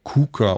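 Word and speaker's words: Quooker: Wie spricht man es richtig aus? – Kuka. – Kuka. Kuka. Ja, so. – Das ist holländisch, das weiß man doch.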